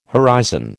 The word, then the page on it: horizon: American English